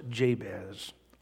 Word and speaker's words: Jabez: This man's name is pronounced Jabez.